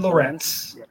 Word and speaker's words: Lorenz: No, I mean, we're really excited for, I believe it's Lorenz. Lorenz, is that how you pronounce it? – Yes, Steven Lorenz.